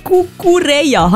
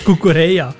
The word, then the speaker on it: Cucurella: Der Cucurella, habe ich es richtig ausgesprochen? Nicht richtig, der Marc Cucurella.